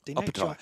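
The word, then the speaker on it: Opetaia: But last night, Jai Opatai, did I pronounce that correctly? – Opatai.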